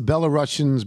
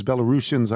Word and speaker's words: Belarusians: Um, you, you mentioned the Russians, the Belarusians, Belarusians, I guess it's better pronounced, who are not there.